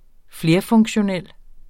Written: flerfunktionel

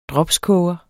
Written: dropskoger

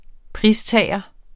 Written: pristager

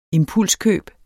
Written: impulskøb

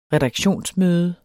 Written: redaktionsmøde

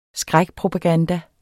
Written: skrækpropaganda

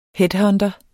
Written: headhunter

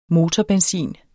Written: motorbenzin

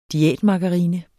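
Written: DIÆTMARGARINE

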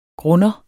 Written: GRUNDEREN

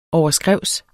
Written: overskrævs

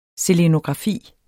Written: selenografi

